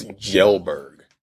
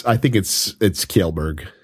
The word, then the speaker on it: Kjellberg: His name is Felix Gelberg. Is that how you pronounce his last name? – I think it's Kjellberg. He's Swedish.